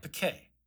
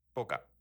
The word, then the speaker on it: poke: Poke. – Like, that's... – Poke. – Oh, you're looking up poke. Okay. – Poke. – Poka.